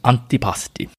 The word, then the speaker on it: antipasti: I know a lot of people call it antipasti or antipasti or whatever, but the correct pronunciation is antipasti.